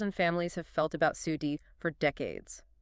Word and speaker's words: SUDEP: SUDEP, S-U-D-E-P, sudden unexpected death in epilepsy. – And we really need to pause here on the pronunciation and the gravity of this. It's pronounced SUDEP, like one word.